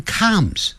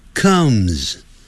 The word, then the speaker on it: comes: 不要念成 comes，就克肚子饿的饿克饿 comes， so she comes home，她就回家啦。